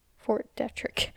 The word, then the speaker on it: Fort Detrick: Russell Byrne worked in the bacteriology division of the Fort Derrick. I think I'm pronouncing that wrong. It's Detrick. Fort Detrick. I've been saying Derrick this whole time. I just realized there's a T in it.